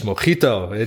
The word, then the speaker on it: Mojito: Dus ik dacht, hey James, het is Mojito. Die J die spreekt als G uit.